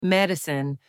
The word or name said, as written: medicine